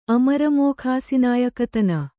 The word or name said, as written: ಅಮರಮೋಖಾಸಿನಾಯಕತನ